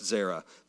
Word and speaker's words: zera: Her seed, that woman, or that word translates as Zera. It's pronounced Zera, the word seed, that is.